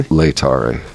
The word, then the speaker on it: Laetare: Another thing we have coming up this Sunday is Laetare Sunday. Am I pronouncing that correctly? – Laetare. – Okay.